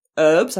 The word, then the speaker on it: herbs: Herbs? I don't know what an herb is. I'm sorry about that. – That's how Americans say herbs.